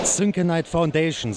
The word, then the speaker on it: Syncanite Foundation: Synchronite Foundation, so spricht man das Ganze aus.